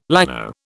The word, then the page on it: llano: British English